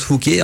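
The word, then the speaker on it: Fauguez: ça s'écrit parfois Fouguet, mais ça se prononce Fouquet, entre Fouquet et Braine-le-Comte, non loin donc du plan incliné de Ronquière.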